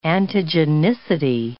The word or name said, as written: antigenicity